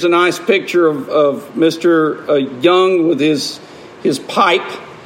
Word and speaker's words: Jung: And so he said he likes to quote Carl Jung, who was, or Carl Jung, I think it's pronounced with a Y.